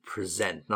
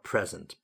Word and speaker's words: present: And important here to know it's a verb, so we pronounce it present, not present, present.